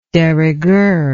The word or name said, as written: de rigueur